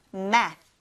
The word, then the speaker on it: math: math, math, math. Very good. th 발음으로 끝나니까 math 한 다음에 이제 혀를 윗니, 아랫니 사이에다 이렇게 넣어서 math, th 발음을 해주셔야 돼요.